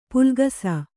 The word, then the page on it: ಪುಲ್ಕಸ: Kannada